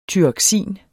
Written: tyroksin

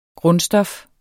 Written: grundstof

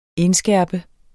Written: indskærpe

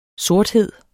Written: sorthed

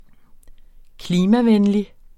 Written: klimavenlig

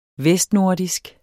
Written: VESTNORDISKE

